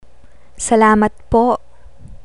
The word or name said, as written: salamat po